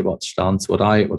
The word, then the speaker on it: Stans: So Stans, that's pronounced correctly.